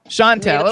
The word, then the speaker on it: Chantel: Is it Chantel? Is that how we pronounce it? – Yeah, that's cool. – Is that correct, though, or you can correct me? – Chantel, okay, Chantel. So okay, we're gonna start. – It's French, so, Jim.